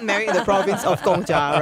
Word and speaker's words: Gong Cha: But that's how it's pronounced. That's Chinese, Gongcha. Those are the words.